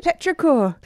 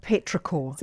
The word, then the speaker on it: Petrichor: Petrichor. Have I said that right? – Petrichor. – Petrichor.